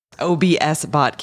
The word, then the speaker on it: OBSBOT: I pronounced it incorrectly last time. OBSBot camera. Because you did an entire stream the other day into the Ecamm community showing the quality.